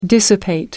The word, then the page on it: dissipate: American English